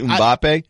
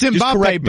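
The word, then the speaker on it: Mbappé: Because I just called him Mbappe, and it's Mbappe. – It's Mbappe, but I like Mbeep.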